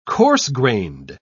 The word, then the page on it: coarse-grained: British English